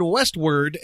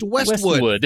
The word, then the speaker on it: Westwood: First, he said Westward and Wilshire. It's pronounced Westwood. There's no R in it.